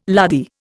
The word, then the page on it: lardy: Indian